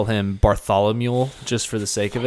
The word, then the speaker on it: Bartholomew: Ooh, Bartholomew. – Yep, there you go. Just say it like Barthol and then think, oh, a mule's over there. Mm-hmm. Bartholomew. – So your friend Bartholomew.